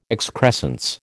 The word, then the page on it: excrescence: American English